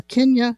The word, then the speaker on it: Kentia: But there's a Kentia, K-E-N-T-I-A palm.